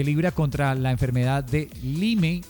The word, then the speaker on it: Lyme: Digámoslo, como está escrito, Lyme.